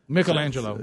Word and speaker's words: Michelangelo: This is going to help a little bit on the pronunciation of Angelo. Mm-hmm. It's say it like Michelob. – Michelangelo.